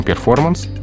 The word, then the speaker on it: перформанс: И, в общем-то, нет никаких оснований этот звук в речи выпускать, потому что все-таки мы произносим не по-английски, а по-русски. Поэтому перформанс — это слово, да, заимствованное из английского языка.